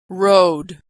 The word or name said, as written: rode